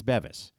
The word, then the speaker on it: Beavis: And not included in their pronunciation was their top player, B-E-V-I-S. How would you pronounce B-E-V-I-S? I went with Beavis. There's a Lauren Beavis on the South Florida softball team. It's Bevis. We had it right for the second half.